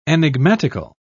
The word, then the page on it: enigmatical: British English